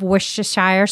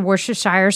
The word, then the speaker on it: Worcestershire: 1 teaspoon of Worcestershire sauce. I know, I'm not saying that right. – You know, Worcestershire sauce.